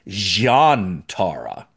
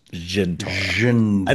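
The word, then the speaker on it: Zhian'tara: He says Jantara. Okay, he goes Jantara, and then Sisko says Jantara with a J. So there's like seven different pronunciations of it, which is fine. Everyone can pronounce it whatever, however they want it. But I'm going to pronounce it the way Dax does, which is Jintara.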